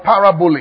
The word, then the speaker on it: parabole: Now, the word parable here is the Greek word parabole. I'm going to spell it for you. P-A-R-A-B-O-L-E.